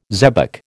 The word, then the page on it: zebec: American English